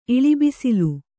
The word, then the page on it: ಇಳಿಬಿಸಿಲು: Kannada